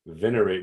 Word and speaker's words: venerate: Venerate me and venerate. It's on the first syllable, but hey, it's all right.